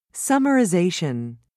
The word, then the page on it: summarization: British English